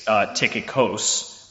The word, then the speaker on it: Tychikos: So the first thing I want to say is that there's been a little bit of conversation about how Tychicus or Tychicus is pronounced, and in fact it is Tychicus if you go back to the Greek. And more specifically, it's Tychikos with a K-O-S on the end of it if you go to the original translation.